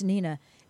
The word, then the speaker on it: Hnina: It's spelled H-N-I-N-A, but it's pronounced Nina.